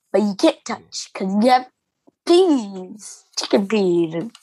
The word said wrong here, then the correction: chickpea: Chickpeas. There's no E-N, just chick.